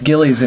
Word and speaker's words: Gillies: And then people mispronounce Gillies and it's like, I'm out of names. – Yeah. Like, how much easier can I make it for you? – What's the problem? It's a bar in Texas.